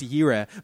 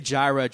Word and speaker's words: Jireh: It's actually pronounced Yireh, but we're going to say Jireh just because it sticks with the brand of the song.